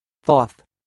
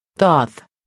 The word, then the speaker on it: Thoth: Thoth. Thoth.